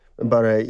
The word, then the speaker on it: Barre: That's correct, Mohamed Siad Barre. – Barre, yes.